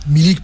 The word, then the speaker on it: Milik: comme les Milic qui vont arriver, les joueurs Milik, cadres. – Milik, pardon, excuse-moi, je prononce mieux Hawik.